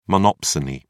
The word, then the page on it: monopsony: British English